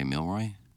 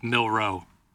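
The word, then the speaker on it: Milroe: Did I not say Milroy? – It's Milrow. Jalen Milrow.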